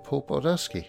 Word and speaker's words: Paul Baldowski: Ectoplasm episode 98, which will be Marvel 1602, and I am absolutely delighted to welcome a guest to the Ectoplasm podcast, Mr. Paul Baldowski.